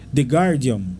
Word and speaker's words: The Guardian: Tem um jornal aqui da Inglaterra, vamos ver se eu pronuncio direito aqui. The Guardian, tá certo? – Isso.